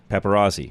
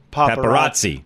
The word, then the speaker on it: paparazzi: Paparazzi. I'm not saying that right, am I? – Paparazzi. – Get the T's where the Z's are, the T's.